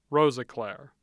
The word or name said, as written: Rosiclare